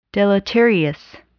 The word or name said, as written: deleterious